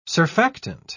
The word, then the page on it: surfactant: British English